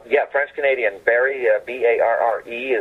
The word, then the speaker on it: Barre: And there is a Barry, Vermont, pronounced Barry, like a Wilkes-Barre, Pennsylvania, is also spelled B-A-R-R-E.